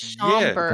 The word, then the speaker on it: Schaumburg: Schaumburg? Is that how you say it? – Schaumburg.